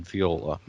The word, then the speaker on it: Viola: Now, Violet, and I like the spelling, is V-I-O-L-E-T-T-E. Her real life name is Viola, right? Or Viola, how do you pronounce that? – I pronounce it Viola.